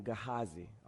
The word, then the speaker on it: Gehazi: When the servant of the man of God, I believe this to be, I think you pronounce his name, Gehazi.